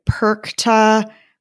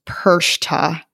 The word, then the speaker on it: Perchta: So it's Frau, and I looked up, it looks like people pronounce it Perkta, Perchta. I'm sure there are other ways that people pronounce it, but I believe from my years in German class that it's pronounced Perchta, like SH, not a hard CH.